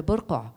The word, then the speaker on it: burqa: je vais prononcer comme on dit en arabe, le burqa.